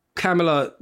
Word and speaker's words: Kamala: She says that we're supposed to pronounce it as in Kamala, like as in you put a Kamala at the end of a sentence. – Mm-hmm. – Kamala, as in the punctuation mark.